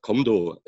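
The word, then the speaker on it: Komdo (검도): But even here in the, well, they pronounce it Komdo, Kendo Komdo in Korean, and it's usually written Kumdo.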